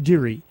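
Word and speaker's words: Deiure: He spells it with way too many vowels and not enough consonants. – It's D-E-I-U-R-E. – Right. – Deary. – Pronounced Deary.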